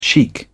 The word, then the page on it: chic: British English